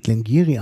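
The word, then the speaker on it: Glen Garioch: Obwohl, wenn man das liest, was da draufsteht, da steht Glengarioch drauf, aber es wird Glengarry ausgesprochen.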